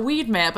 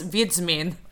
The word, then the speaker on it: Wiedźmin: I'm Geralt, the Weedman. But no, it's Wiedźmin, which sounds nothing like Weedman.